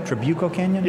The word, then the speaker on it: Trabuco Canyon: Did I say it right? Trabuco Canyon? – Yes.